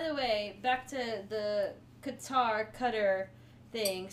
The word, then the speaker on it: Qatar: It's pronounced like um, one of the characters in Avatar: The Last Airbender, Katara. Mm. – So it's Qatar.